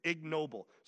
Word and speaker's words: ignoble: Some are for noble purposes, and some for, how do you pronounce, is that ignoble? I put the G in there because I always want to think that maybe the G is silent. It's ignoble.